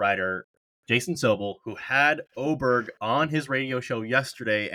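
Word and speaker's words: Åberg: I think we're saying that correctly now. I would still like to say Ober, but I also wanted to say Aberg originally. We'll settle on Oberg. I'm not changing that name again.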